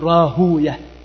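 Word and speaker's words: راهويه: روى ابن أبي حاتم في العلل قال: سمعت أبي، وذكر الحديث الذي رواه إسحاق ابن راهوية، المحدثون يقولون: ويه شيطان، وأكثر الناس ينطقون راهوية راهوي، وهكذا في نظائرها يقولون إسحاق ابن راهوي، والمحدثون يقولون كما ذكر ذلك الحافظ السيوطي في التدريب إن المحدثين يكرهون ويه، يقولون: ويه شيطان، فلا ينطقونها هكذا، لا يقولون راهوي، إنما يقول راهوية، ننطقها هكذا لكراهتهم للشياطين ولكراهتنا لهم أي للشياطين أيضا.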